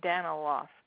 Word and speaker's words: Daniloff: Eileen, starting with an E, Daniloff. D-A-N-I-L-O-F-F, like turning off something.